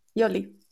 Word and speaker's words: Yoli: Yes, Yoli. Yeah. – Because I sometimes I'm thinking it's Jolly. Could be. jolly. Yeah. yeah, it's, it's okay. Like jolly good.